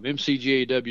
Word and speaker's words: McGaw: MCGAW is McGaw. So it's got some silent C in it.